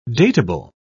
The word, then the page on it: dateable: British English